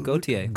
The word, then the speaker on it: Gauthier: Yeah, Gauthier. Like the singer.